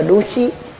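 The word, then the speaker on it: Dūsī: Xin lỗi, cái Dushi nó không có cái không có nhiễu. Đọc là đú xí.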